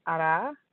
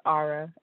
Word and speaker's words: Arah: my first name is Arah, or Arah, as most Americans pronounce it.